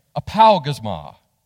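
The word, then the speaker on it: apaugasma: Okay, apalgasma. That's fun, right? Apal, think pow.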